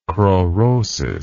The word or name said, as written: kraurosis